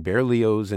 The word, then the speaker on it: Berlioz: And speaking of the French, do go ahead and pronounce the Z's in Berlioz and Boulez, and don't be fooled by the letters E-N-C.